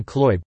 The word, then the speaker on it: Kloib: Like the widget company's CEO, Marvin Kloib, the O is silent, who made a video of himself asking the cleaning lady for business advice.